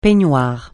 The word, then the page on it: peignoir: francais